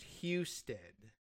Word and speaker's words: Husted: Apparently, the local pronunciation is Husted. – Houston. – Yeah, right. So, yeah, like the like Houston, but without the O. But then every once in a while, you run into a H-O-U that's pronounced Huston anyway.